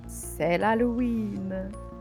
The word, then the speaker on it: C'est l'Halloween: C'est l'Halloween.